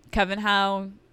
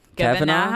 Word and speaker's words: Kavanaugh: Um, Coven How. – Kavanaugh.